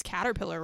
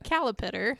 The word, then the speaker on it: caterpillar: I had to pronounce caterpillar wrong for years because I was so invested aww. – In Gray continuing to say it incorrectly. – How did she say it? – Calipitter.